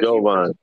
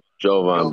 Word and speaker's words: Jovan: I am joined by, how do you say it, Jovan or Jovan? Because I know there's different ways. – Jovan. Jovan, but yeah, my name has been getting thrashed for a long time. So Jovan.